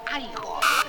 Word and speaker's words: Igor: No, se pronuncia Igor.